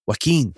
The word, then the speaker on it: Joaquin: Joaquin. – Is that how you pronounce it? Joaquin? – Yeah, Joaquin Phoenix.